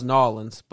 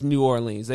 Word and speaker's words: New Orleans: I thought it was Nawlins, but it's not. I thought that's how they pronounce it. – That's how they pronounce it. – But no, it's New Orleans. They pronounce it normal, which is hilarious.